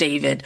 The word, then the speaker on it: D4VD: It's spelled D4VD. It's pronounced David,